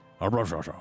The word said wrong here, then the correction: Araj: I don't know why I have so much trouble pronouncing this name. It's A-R-A-J. Araj.